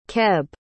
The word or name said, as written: Keb